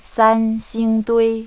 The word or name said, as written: sanxingdui